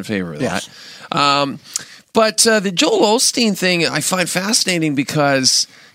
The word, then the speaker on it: Joel Osteen: But, but you know, that's interesting, like that Joel Osteen.